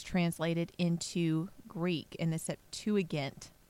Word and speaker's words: Septuagint: I always feel like I'm saying that wrong. – You are. – Which is, I am. How is it? Septuagint. – Septuagint. Okay, got it.